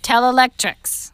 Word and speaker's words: Telelectrix: Tell Electrics.